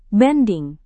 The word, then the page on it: banding: indonesian